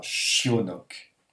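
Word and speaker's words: chionok (щенок): Yo-n-o-k, tout ça, ça se prononcera chionok. Chionok. En insistant bien sur le ch. C'est pas un che, c'est un ch qui accroche un peu, vous voyez, si on le retient un petit peu. Chionok. Chionok, c'est un chio.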